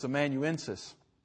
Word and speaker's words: amanuensis: It's amanuensis. And Mark pronounced it hurriedly last week and made a little bit of an extra syllable in there or something.